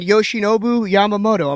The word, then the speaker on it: Yoshinobu Yamamoto: Yoshinobu Yamamoto. Am I saying that right? – Wow, look at you, kid. – Did I say it right? – On the nose.